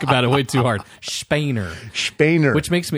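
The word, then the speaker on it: Spener: And we were going to guess on how to say his name, but we listened to some German people speaking English, and they said Spainer.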